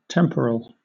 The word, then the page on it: temporal: British English